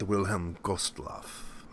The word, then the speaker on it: Wilhelm Gustloff: Wilhelm Gustloff.